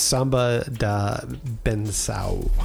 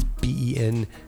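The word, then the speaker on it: Bênção: By the way, it's pronounced it's Samba da Benção. – Yeah. – So it's Bencao. If you're looking for it.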